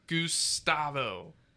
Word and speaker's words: Gustavo: It's not Gus, it's Goose! – I guess. Again, the first three letters is Gus. – Yeah, I feel you, but the first syllable is Goose. Gustavo.